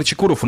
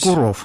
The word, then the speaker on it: Чекуров: Александр Чекуров у нас паралимпийский чемпион, чемпион, а ты меня поправляй, хорошо, если я ошибусь. – Чекуров. – Чекуров. – А, да, на «о» ударение.